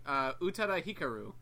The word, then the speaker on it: Utada Hikaru: Utada Hikaru.